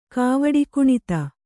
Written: ಕಾವಡಿಕುಣಿತ

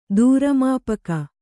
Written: ದೂರಮಾಪಕ

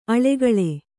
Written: ಆಳಿಗೊಳ್

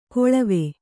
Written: ಕೊಳವೆ

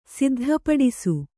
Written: ಸಿದ್ಧಪಡಿಸು

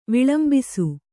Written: ವಿಲಂಬಿಸು

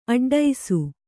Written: ಅಡ್ಡೈಸು

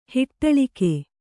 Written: ಹಿಟ್ಟಳಿಕೆ

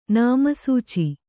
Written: ನಾಮಸೂಚಿ

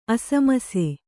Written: ಅಸಮಿಸಿ

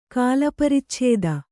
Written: ಕಾಲಪರಿಚ್ಛೇದ

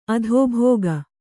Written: ಅಧೋಭೋಗ

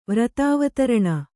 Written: ವ್ರತಾವತರಣ

